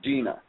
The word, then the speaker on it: Deana: And for those of you that haven't watched it, she spells her name Deanna. D-E-A-N-A, but she keeps saying, no, I pronounce it Dina.